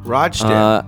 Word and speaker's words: Rochdale: idyllic town of Rochdale. – Rochdale.